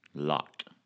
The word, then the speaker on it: Locke: Now, there's a quarterback for somebody, college quarterback, whose last name is Lockie, but It's L-O-C-K-I-E. So that's how you pronounce Lockie. That's Lockie. – That's Lockie. – L-O-C-K-E is a lock. I have the pretentious silent E in my name.